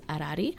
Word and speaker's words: Arari: Um, but her name Arari um, is actually an Oromo word. the way you pronounce it is Arari, and so it means peace.